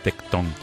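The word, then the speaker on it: Tecton: Mm, tectón. Tal como suena, ¿verdad? Tal como suena. No hay acento porque es una palabra griega, pero eh, está, la tilde, digamos, está en la O.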